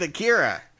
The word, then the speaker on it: Akira: I'm going with Akira.